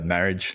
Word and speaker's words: Marriage: Yeah, look, in high school, it was marriage, and yeah, it is French, but we'll just go with marriage.